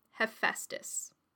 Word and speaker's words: Hephaestus: And he said, I would say Hephaestus.